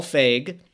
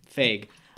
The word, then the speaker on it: Feig: So the director is Mr. Paul Feig. – That's how you say his name. – Yeah, Feig.